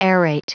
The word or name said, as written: aerate